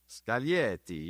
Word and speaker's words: Scaglietti: How do you say Scaglietti? Anybody know how to say that? My co-hosts? – Scaglietti. – Scaglietti. – Scaglietti, yes, okay.